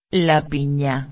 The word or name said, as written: la pina